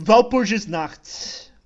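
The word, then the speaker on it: Walpurgisnacht: Ok, enquanto você abre, eu só vou deixar um adendo que o nome correto da Brazilian Night é Valpurgis Night. Tá certo? Minha pronúncia? – Valpurgis. É, Valpurgis Nacht.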